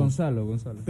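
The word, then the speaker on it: Gonçalo: ¿Se pronuncia Goncalo Ramos? – Gonzalo. – Pero se escribe, bueno, es que se pronuncia Z, ¿no? Sí.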